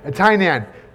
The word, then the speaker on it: Tainan: We visited Taiwan recently, and the brothers there, we visited one locality, Tainan. I probably didn't say it right, but Tainan? Yeah, Tainan. Tainan has districts, praise the Lord.